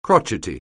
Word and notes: crotchety: anglais

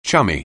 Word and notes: chummy: anglais

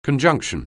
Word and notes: conjunction: anglais

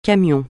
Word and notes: camion: francais

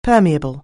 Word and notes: permeable: anglais

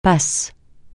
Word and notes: passe: francais

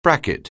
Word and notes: bracket: anglais

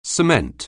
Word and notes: cement: anglais

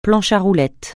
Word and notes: planche à roulettes: francais

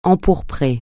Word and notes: empourprer: francais

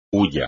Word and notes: hulla: espagnol

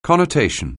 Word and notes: connotation: anglais

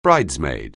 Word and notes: bridesmaid: anglais

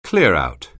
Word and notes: clearout: anglais

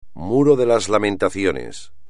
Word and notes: Muro de las lamentaciones: espagnol

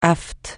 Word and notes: aphte: francais

